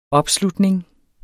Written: opslutning

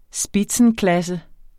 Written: spitzenklasse

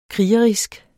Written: krigerisk

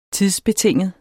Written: tidsbetinget

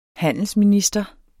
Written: handelsminister

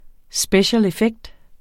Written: special

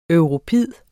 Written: europid

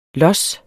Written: los

